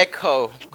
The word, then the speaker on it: Kohh: Yeah, Kohh.